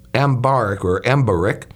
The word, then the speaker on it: Embarek: His name is Peter Ben Embarek, I guess you pronounce it, Embarek.